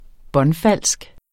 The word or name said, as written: båndfalsk